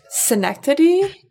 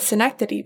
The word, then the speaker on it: synecdoche: Our favorite is Sinakina Dodachodi, which is synecdoche, which to this day, I've been saying that I've been saying the non-word word for months, and I still can't remember what synecdoche means.